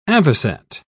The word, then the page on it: avoset: British English